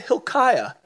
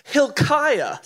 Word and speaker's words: Hilkiah: Somebody say Hilkiah. Not milk, but Hilk. Hilkiah. Say it again. Hilkiah. Say it like you're like angry at somebody. Hilkiah. Sounds like Al-Qaeda, but it's not.